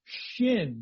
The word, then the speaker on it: Shin: There would be a dot above the right side of this letter that looks like a big W, and that would be pronounced Shin, just like the letter is Shin. And yet, there is also the same consonant, same letter with a dot on the left side at the top. And that's pronounced Sin. Sin. Again, it's more like Sheen and Seen.